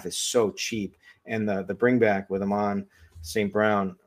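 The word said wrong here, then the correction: Amon-Ra St. Brown: I think you might've forgotten a syllable in there somewhere. – Yeah, there. That's what I think. Amon Ross St. Brown.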